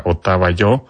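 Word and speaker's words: Otava Yo (Отава Ё): Luego vamos a irnos ya mucho más para acá a escuchar a Otava Yo, que se escribe así, Otava, y el Yo es una E mayúscula con diéresis,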